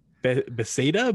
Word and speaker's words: Bethesda: Bethesda. – Bethesda.